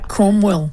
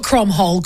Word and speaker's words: Cromwell: Cromwell. Not Cromhole.